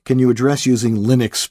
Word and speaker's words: Linux: Linux.